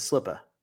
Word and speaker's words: slippa: It's slipper. – So slipper? – Slipper. – Spell it. – S-L-I-P-P-A.